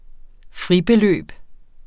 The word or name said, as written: fribeløb